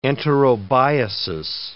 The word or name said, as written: enterobiasis